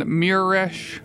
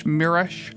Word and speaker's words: Mieresch: Before that, you heard a song from the band Miresh. I believe that's how you say it. M-E-M-I-E-R-E-S-C-H, Miresh.